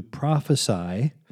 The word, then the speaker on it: prophesy: To prophesy, notice the S in the word, which makes it a verb instead of a noun, and it's pronounced like sigh, is to speak before, profess, or to tell out, to speak before people, not to speak before it happens.